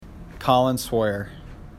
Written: Swoyer

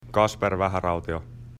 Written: Vaharautio

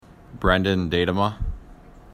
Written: Datema

